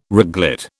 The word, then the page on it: reglet: British English